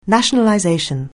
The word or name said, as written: nationalization